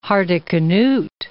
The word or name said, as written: Hardicanute